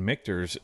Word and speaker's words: Michter's: The first time Michael has, on the first try, gotten Michter's announced and pronounced correctly.